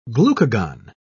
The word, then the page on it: glucagon: British English